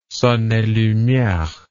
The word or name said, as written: son et lumiere